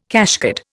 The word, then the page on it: cashcard: Indian